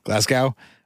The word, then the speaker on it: Glasgow: You have selected the Glasgow? How do you say that place? – Glasgow.